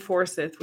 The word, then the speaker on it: Forsyth: Forsyth. – Where is that? – In the mountains. What? Mecklenburg has four. Is that Cumberland with two? And Wake with two. And Forsyth with two.